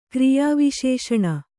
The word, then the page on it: ಕ್ರಿಯಾವಿಶೇಷಣ: kriyā viśeṣaṇa